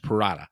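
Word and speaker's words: Pirotta: and Persia Peralta. And believe it or not, Peralta gets the win here. Or Parada, I'm sorry. I keep saying her name wrong. It's Parada.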